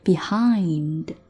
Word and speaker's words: behind: behind 中间这个双元音哈，注意很多人会读成 behind，它是 ein 双元音 behind。